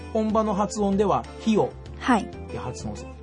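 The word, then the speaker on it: Rio: あの言葉の一番前に来る R は、うんうん、ハ行の発音になります。 – ヒオデジャネイロとか。 – そうですね。リオじゃなくてヒ、リオじゃなくてヒって言ってますよね。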